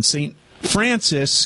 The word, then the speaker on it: St. Francois: St. Francis County, even though it's spelled St. Francois.